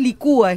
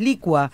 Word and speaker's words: licua: Por ejemplo, yo siempre, viste que se dice evacúa siempre, y es evacua en verdad. Lo mismo que no es licúa, es licua. – Claro. – Y la mayoría de las veces se escucha con el acento en la U.